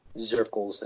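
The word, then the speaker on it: Dzierkals: Dzirkels, I think is how it's pronounced.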